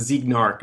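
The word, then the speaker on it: Zeigarnik: I think, or is that Zeignarik, I think? – It's the Zeignarik effect. – Zeignarik effect. Okay, well, yes. – I've been saying it wrong this whole time.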